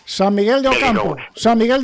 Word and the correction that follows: San Miguel do Campo: de Ocampo, lo digo bien, ¿a que sí? – Efectivamente, San Miguel do Campo. – Do Campo, sí.